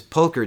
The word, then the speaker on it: Pulcher: P-U-L-C-H-E-R. What do you think? – Well, didn't we say that the C is a K? – Oh, Pulcher.